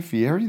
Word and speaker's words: Fieri: We have to pronounce it with the Italian accent. – Fieri.